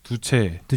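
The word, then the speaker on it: Duce: 그 스펠링을 보면 D U C H예요. 근데 그거 읽으면 두채 두채.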